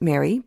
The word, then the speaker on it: Mary: It's by a popular rock group named Mary, pronounced Mary, but you gotta roll that R.